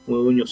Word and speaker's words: Modugno: Modugno se pronuncia como N.